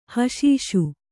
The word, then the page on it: ಹಷೀಷು: haṣīṣu